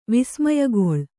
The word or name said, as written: ವಿಸ್ಮಯಗೊಳ್ಳು